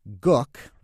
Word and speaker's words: Gook: There was Vic and Sade Gook, rhymes with look, their foster son Rush, and then an Uncle Fletcher.